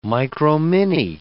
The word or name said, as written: micromini